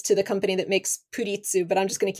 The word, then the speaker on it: Pretz: They're called puritsu, but it's spelled P-R-E-T-Z. And I cannot look at it without just thinking pretz because it looks like the word pretzel. But for some reason, it's not pronounced that way.